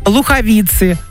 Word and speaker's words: Луховицы: У нас в России 3 столицы: Москва, Питер, Луховицы. Вот так надо запоминать ударение, я поняла вас.